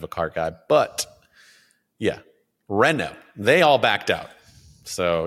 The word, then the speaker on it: Renault: Mercedes, Audi, Volvo, Polestar, and was it Renault? Is that how you say it? Or am I going to get made fun of for not saying it correctly? – It's Renault.